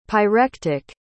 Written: pyrectic

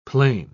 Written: plain

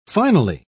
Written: finally

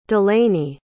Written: delaney

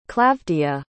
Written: Klavdiya

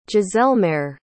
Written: giselmaere

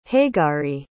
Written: hegari

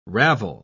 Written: ravel

